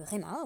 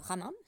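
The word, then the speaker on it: Rema: En réalité, dans le commentaire du, du Réma, ou du Rama, selon les prononciations,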